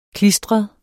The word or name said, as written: klistret